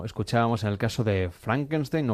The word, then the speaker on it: Frankenstein: Marty se pronuncia Frankenstein, como en alemán.